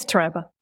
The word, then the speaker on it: Taraba: Yes, Taraba.